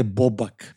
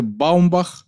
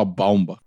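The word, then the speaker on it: Baumbach: Δεν λέγεται Μπόμπακ. Εγώ πιστεύω ότι προφέρεται Μπάουμπαχ. Το Μπάουμπακ μου πάει λίγο αλαμπάλακ, δεκάρι, παλιό, παλαιά κοπή.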